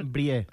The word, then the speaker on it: Brie: Brie. – Brie, sí. Jo no sabia que es pronunciava així. – No, es diu bri.